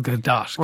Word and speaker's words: Gadot: I may not be able to spell, but I can definitely pronounce Gal Gadot.